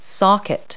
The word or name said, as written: socket